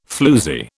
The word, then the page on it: floozie: British English